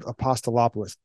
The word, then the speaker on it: Apostolopoulos: Steve Apostolopoulos. That's how you pronounce it there, young lady.